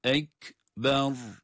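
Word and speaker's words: ekber (أكبر): El ekber. Onun için bu kelimeyi okurken asla uzatarak okumuyoruz. Ray'da kalın okuyoruz. Ek, ek, ber, ber.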